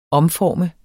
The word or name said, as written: omformer